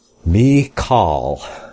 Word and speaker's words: Michal: And Saul gave him Michal, his daughter, to wife, or Michal, however it's pronounced. Michal, that's what we have in the pronunciation here. Michal, his daughter, to wife.